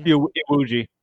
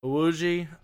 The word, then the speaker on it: Iwuji: And then Jesse, how do you pronounce his last name? – Jesse Iwuji. – Iwuji. – Iwuji, yeah.